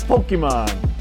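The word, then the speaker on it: Pokémon: It's Pokemon.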